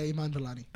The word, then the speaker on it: Iman Vellani: Iman Vellani? – Yeah, that's close, I think. – What is it? – Her name? – I-M-A-N. Iman, yeah, Vellani. – Yeah, Iman Vellani.